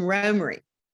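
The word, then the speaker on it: Romare: It was a friend of his great-grandfather's, and the family pronounced it Ramare, and I think you may have pronounced it that way as well. Tell me a little bit about how the pronunciation has varied over time. – Well, he never corrected anybody because it's just a good policy not to correct anybody pronouncing your name. They called him Romery. – Romery. Okay. – Romery. He says that's the way he spells it out phonetically.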